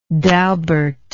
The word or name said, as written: Dalbert